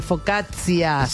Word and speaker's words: Focaccia: Focaccia.